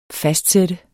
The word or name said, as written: fastsætte